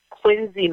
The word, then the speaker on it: Quincy: That's here, and they pronounce it Quincy, Massachusetts, although it says Quincy.